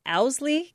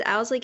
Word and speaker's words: Owsley: Owsley County, is that how you say it? – Yes. Most people don't pronounce it correctly. I think you might be the first the first time. But yes, it's Owsley County, Kentucky.